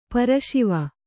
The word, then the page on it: ಪರಶಿವ: para Śiva